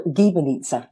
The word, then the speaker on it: gibanica: It's called gibanica. Gibanica. – And uh, gibanica, is a hard G.